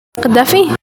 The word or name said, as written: Khadafi